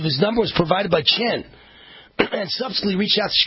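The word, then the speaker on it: Tchen: Reports emerged last week Cook County State's Attorney Kim Fox had been in contact with Mrs. Obama's former chief of staff, Tina Chen, T-C-H-E-N, I think it's pronounced Chen, but who knows, who emailed her expressing the concerns that the Smollett family had about the ongoing investigation.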